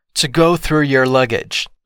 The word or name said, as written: to go through your luggage